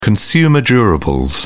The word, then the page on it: consumer durables: British English